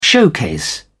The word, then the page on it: showcase: British English